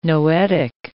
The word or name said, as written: noetic